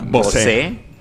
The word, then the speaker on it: Bose: Para nuestros amigos pobres, Bose, es como se pronuncia Bose.